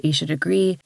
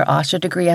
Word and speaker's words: Aisha Degree: People sometimes call her Asha Degree. I've heard her family call her Aisha Degree, though, on the news a good bit. So that's the pronunciation that I tend to use.